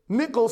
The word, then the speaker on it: Michal: And as the ark of the Lord in verse 16 came into the city of David, Michal, Saul's daughter, by the way, I think I'm pronouncing that right. I might not be. I don't think it's pronounced Michael. I think it's Michal. I could be wrong.